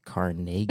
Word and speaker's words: Carnegie: Everybody outside of Pittsburgh pronounces it Carnegie. – I know, because it's the Carnegie Deli. – It's Carnegie.